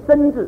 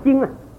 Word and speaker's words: 旌: 意思就是说神是我的旌旗，那个字是念旌字，很多人念身字，不是身啊，是旌啊，西一恩啊，西恩啊，西恩音，西恩西音旌啊，旌啊，念发音发旌字，啊，耶和华是我的旌旗。